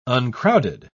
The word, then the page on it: uncrowded: British English